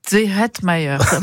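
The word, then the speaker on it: Zehetmair: Thomas Zehetmeier. Il faut prendre le coup, hein. C'est prononciation allemande, c'est Zehetmeier.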